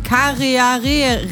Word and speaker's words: Karearea: Ein flugunfähiger Papagei und den Karearea. Das ist ein neuseeländischer Falke, der wird genauso ausgesprochen auf die Plätze.